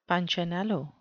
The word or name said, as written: punchinello